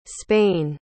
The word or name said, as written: spain